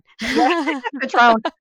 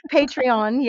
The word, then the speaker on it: Patreon: And so this will be like a subscription service where if you're on Patreon, you get like, am I saying that right? Or am I saying the alcohol again? – Patreon. Patreon.